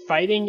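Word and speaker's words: Fhtng: The name is written as F-H-T-I-N-G. Or no, I. It's like literally fighting, the word fighting with no I's in it. So it's F-H-T-N-G. So I think that would be pronounced fighting.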